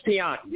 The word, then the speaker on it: Tianki: My name is Tianki. It's spelled T-I-A-N-K-I.